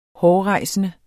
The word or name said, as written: hårrejsende